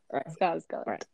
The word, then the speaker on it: Skarsgård: And then the second character focus is with Bill Skarsgård. Is that his name? Did I say it right? – Yeah. – Skarsgård.